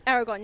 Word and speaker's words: Aragorn: And remember, Viggo was the second guy to play Aragorn in that movie. I'm saying that right. That was his character name, right? – Aragorn, yeah.